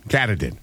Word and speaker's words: Katahdin: Katahdin. – Katahdin. – I think that's how it's pronounced. – Yeah, that's it.